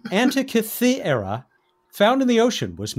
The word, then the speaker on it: Antikythera: As I said, in the Netherlands, she indeed said it's the Antikythera.